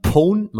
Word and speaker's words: pwn: Und zwar heißt das, wenn man das Gerät oder das Device wirklich hackt, dann pwnt man es. Und das wird ausgesprochen mit PWN, also pwn.